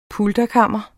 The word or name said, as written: pulterkammer